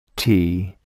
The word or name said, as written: tee